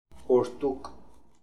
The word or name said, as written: Boordook